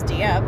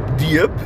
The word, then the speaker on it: Dieppe: Is that how you say it in French or is it different in French? – I believe it's Dieppe. – Dieppe? – Dieppe.